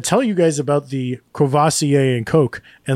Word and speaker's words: Courvoisier: I lose that first R, and I go Courvoisier. – Courvoisier, that's what I know it as, too.